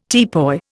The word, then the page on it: teapoy: Indian